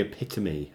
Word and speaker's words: epitome: Probably Epitome, I think you'd say that. – Really? – Yeah. That's how you say that word.